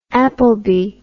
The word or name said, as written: appleby